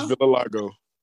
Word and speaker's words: vitiligo: Wait, is that how you actually say that word? – That's not how you say it, but I know what you mean. – No, okay. No, because I was like, that don't sound right. I thought it was vitiligo. – It's vitiligo.